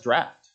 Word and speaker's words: draught: It's pronounced draft. How many knew that? The word's draft. They brought in a great draft, not a great draw deficiency. The G-H makes an F sound, kind of like a P-H would.